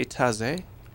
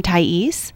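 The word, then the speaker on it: Thaïs: Did I hear you say taze? – No, it's meditation from Thais. It's a French piece of music, and there's a little bit of a story behind it.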